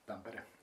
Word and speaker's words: Tampere: I had great sauna sessions in Tampere, and did I say that right? – Tampere. – Yeah. Tampere, yeah.